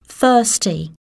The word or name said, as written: thirsty